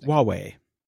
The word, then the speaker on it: Huawei: Poland arrests Hui and Orange employees in spying case. – It's actually pronounced Huawei.